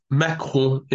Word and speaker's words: Macron: His name is Macron in French, and it basically rhymes with macaron, a cake.